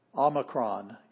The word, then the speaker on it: Omicron: Omicron, it is the short O sound. Now there's some people who will pronounce that long O, but then you have no distinction between that and an omega, because omega is the long O.